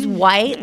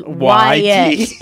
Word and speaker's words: Wyatt: And it was I used to think it was white, like the color. It was Wyatt. It was W-Y-A-T-T. And just everybody pronounced it wrong.